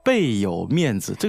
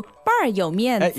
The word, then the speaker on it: 倍儿有面子: 嗯，但是你读的时候呢，你千万不要读成倍有面子，这个就是完全没有这个感觉了。 – 嗯，一定要读倍儿有面子。 – 哎，一定要加个儿在里面。